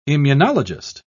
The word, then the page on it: immunologist: British English